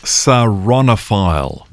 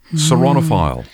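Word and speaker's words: ceraunophile: All right, your word today is saronophile.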